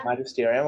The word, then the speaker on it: Magisterium: But in with the Pope and the kind of, I'm going to butcher this name, but the Magisterium, is it called? – Magisterium, okay.